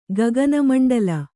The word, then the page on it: ಗಗನಮಂಡಳ: gagana maṇḍaḷa